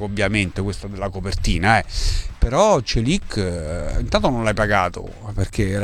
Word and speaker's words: Çelik: si pronuncia Çelik, Çelik, tutti dicono Çelik, però ha detto Çelik.